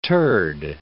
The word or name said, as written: turd